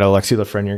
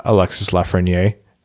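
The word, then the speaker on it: Alexis Lafrenière: I had a real nightmare the other day that Alexi Lafreniere got onto the stage and uh, he pronounced his name uh, Alexis Lafreniere. And I was like, oh no, we have so much material out there saying it wrong.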